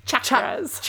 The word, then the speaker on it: chakras: Is this about chakras? Did I say that right? – Chakras. – Chakras. It's not sha, it's cha.